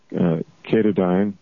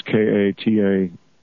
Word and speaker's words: Katadyn: I've heard it pronounced Katahdin. It's Katadyn.